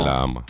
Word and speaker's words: Llama: Llama.